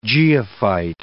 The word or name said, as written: geophyte